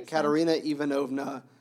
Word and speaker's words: Katerina Ivanovna: The chief characters that are connected to Dmitri are Katerina Ivanovna. No, sorry. Yeah. Did I say that right? Yeah, Katerina Ivanovna.